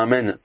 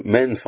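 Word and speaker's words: Amen (אמן): Par exemple, si on dit Amen sans prononcer bien le Noun à la fin. Ou alors pareil, si on avale le Aleph et donc on dit Men sans dire Amen.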